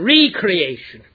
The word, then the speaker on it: recreation: That is the way to pronounce recreation, recreation. We go to our Creator, and He makes us anew.